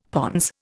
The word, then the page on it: pons: Indian